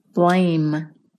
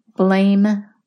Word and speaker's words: blame: The third word is blame. Blame rhymes with name. Now you say it. Blame.